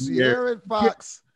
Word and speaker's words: De'Aaron Fox: De'Aaron Fox.